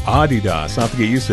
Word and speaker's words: Adidas: Did you know that most of us pronounce Adidas wrong? It's actually supposed to be Adidas. – Really? – Yeah, slight emphasis on the first syllable, not Adidas. – Adidas. – Adidas. – All right. – Yeah, it's German. Just... it's a German company, so.